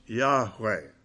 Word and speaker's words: Yahweh: And of course, in Hebrew, that is pronounced Yahweh.